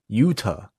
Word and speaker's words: Yuta: Do you feel the like when you see Yuta, it's a hard T rather than the soft T like in Toronto.